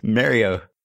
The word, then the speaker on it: Mario: By the way, I apologize to everyone for my non-standard pronunciation of Mario. I'm pretty sure it's a New York thing. – Wait, how do you say it? – Mario.